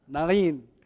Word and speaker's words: Nain: The story that we heard today took place in the city of Nain, as they pronounce it in my church in Arabic, Nain, which is a town in Galilee, close to Nazareth, where Jesus was raised.